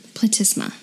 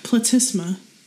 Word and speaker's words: platysma: Platysma. – Platysma.